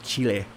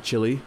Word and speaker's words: Chile: We're not doing too well on the Pacific coast of South America, from Peru down to the middle coast of Chile. That's how you pronounce those damn countries. It's not Chile or Peru. It's Peru and Chile.